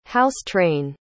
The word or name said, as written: house-train